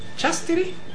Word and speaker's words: Chastity: Chastity.